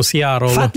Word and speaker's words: Seattle: Gli americani lo pronunciano Seattle.